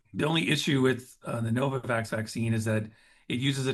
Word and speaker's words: Novavax: Novavax.